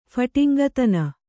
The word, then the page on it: ಫಟಿಂಗತನ: Kannada